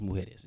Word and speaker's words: Isla Mujeres: Is it Isla Mujeres? I think it might be Isla Mujeres. I don't think that they do silent S's in Spanish. – It was Mujeres.